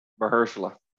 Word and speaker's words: Mahershala: I don't know how to pronounce it. Marisala? – Mahershala. – Mahershala.